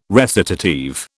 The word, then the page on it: recitative: British English